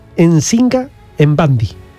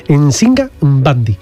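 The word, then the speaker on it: Njinga Mbandi: Pero fijaros, se llamaba Nsinga Mbandi. Así hay que pronunciar las M, ¿eh?, y las N. Nsinga Mbandi.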